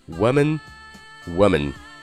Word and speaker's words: woman: 好了，那今天讲了女人的单复数的正确的发音，单数是 woman，woman，复数是 women，women。